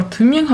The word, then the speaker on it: Thymian: There is also thymian or thymian. How do you pronounce it? – Thymian. – Ah, yeah, because you pronounce it the Y or the Y. – Yeah, like a U with dots.